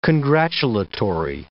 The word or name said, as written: congratulatory